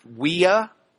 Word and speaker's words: via: I got to tell you, this is the uh, uh, via via in Latin would be pronounced via. That means road.